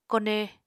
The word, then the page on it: kone: Apatani